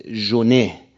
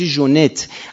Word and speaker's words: ژونت: یعنی هم ژراردش غلطه هم ژونش غلطه. این ژرارد نیست، دی آخرش تلفظ نمیشه، میشه ژرار، اونم میشه ژونت.